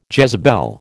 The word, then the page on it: Jezebel: American English